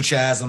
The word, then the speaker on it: Chazz: I'm Mr. Chazz on pretty much all platforms, and that's Mr. Chazz with like two Z's.